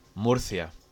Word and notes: Murcia: Spanish

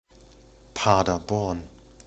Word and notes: Paderborn: German

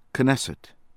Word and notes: Knesset: American English

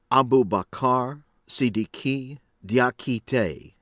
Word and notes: Aboubacar Sidiki Diakite: American English; from Guinea

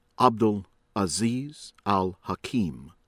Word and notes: Abdul Aziz Al-Hakim: American English

